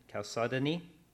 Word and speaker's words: Chalcedony: The third, how do you say that? – Chalcedony? – Yeah, that.